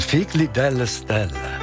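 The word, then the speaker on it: Figli delle stelle: Ich dachte da an Alan Sorrenti und seinem Song Figlie delle Stelle. Hoffentlich richtig ausgesprochen. Bis dann, ich danke dir. Ciao. – Ich glaube schon, Figlie delle Stelle.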